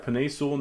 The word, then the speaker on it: Penei Sewell: Did I say that right? – Yeah, Penei Sewell.